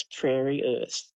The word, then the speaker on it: PrairyErth: And then he wrote a book which probably influenced my novel Mullumbimby a hell of a lot, and that's Prairie Earth. It's spelled PRAIRYERTH.